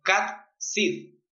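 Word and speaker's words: Cat Sith: En Irlanda, bueno, en Escocia también, pero en Irlanda igual, hay un gato que se le conoce como Cat Sid, que se escribe S-I-T-H. – Como Sid. Como Sid, como Lord como Sid.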